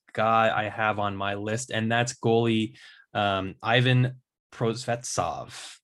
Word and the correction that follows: Prosvetov: How do you pronounce that correctly? – It's Prosvetov. Yeah. – Prosvetov. Thank you.